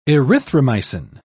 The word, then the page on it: erythromycin: British English